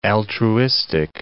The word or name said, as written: altruistic